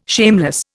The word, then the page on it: shameless: Indian